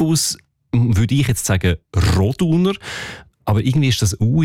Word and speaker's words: Roduner: Also üblicherweise betonen wir ja Namen in der Deutschschweiz, die drei Silben haben, immer auf der ersten Silbe. Also Andereck, Zollinger, Bertschinger, Ochsenbein. In dem Fall ist es aber eher die zweite Silbe, die betont Roduner.